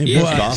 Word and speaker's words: Bischoff: That's how I'd say his name. Bischoff.